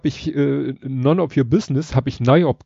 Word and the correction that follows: noyb: gesagt. Und dann hat er nochmal gesagt, ja, die Abkürzung ist aber N-O-Y-B und das ist halt halt NOIP.